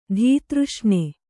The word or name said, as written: ಧೀತೃಷ್ಣೆ